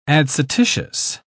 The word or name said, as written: adscititious